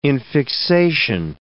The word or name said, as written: infixation